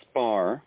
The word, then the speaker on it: Spar: Okay, it's Spar.